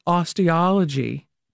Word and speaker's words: Osteology: In fact, you wrote the book, Forensic Osteology.